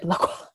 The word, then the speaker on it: Lacroix: And you can't pronounce Christian Lacroix normally. Like, you have to say, like, it's Lacroix, darling. It's Lacroix. Is it Lacroix? Lacroix. – It's like an exhale, isn't it? Lacroix.